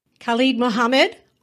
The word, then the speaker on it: Khallid Muhammad: Khallid Muhammad, also known as Kent Jamz.